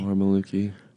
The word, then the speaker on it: Mormolukee: More Maluki. I actually have no idea if I'm saying that the right way, but I think it is right. More Maluki. – More Maluki. – It's spelled M-O-R-M-O-L-U-K-E-E.